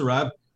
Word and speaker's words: CeraVe: I said, I think it's pronounced Sarav. She said, yeah, it's pronounced Sarav.